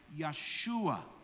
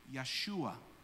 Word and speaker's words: Yeshua: So we pronounce the name Jesus in Hebrew as Yeshua. Yeshua. Okay? Now, Yeshua is actually not the full pronunciation of the name, because the name is actually the exact same as Joshua. So when you look at the name Joshua, it's actually Yehoshua. That's the full form of the name Jesus, is Yehoshua.